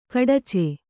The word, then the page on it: ಖಡಿಚಿ: khaḍici